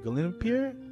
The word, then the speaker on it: Gleipnir: Um, mm, the next anime we saw, we saw uh, Glen Gleipnir. I don't know if I'm saying that right. Well, it's called Gleipnir and um, it's about it's about a boy who has the power to turn into a dog mascot.